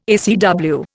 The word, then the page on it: ACW: Indian